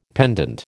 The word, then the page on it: pendant: American English